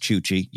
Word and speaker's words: Chuchi: First of all, it's Chuchi. You said it right. Good. No Ashoka mess or whatever. – Ashoka. – It's Chuchi.